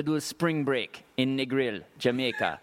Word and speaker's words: Negril: Very, very, very good on the Negril. Because that could have been bad. – For a white boy like me, me have to say the L really hard. Really pronounce the L. – Negril.